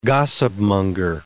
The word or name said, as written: gossipmonger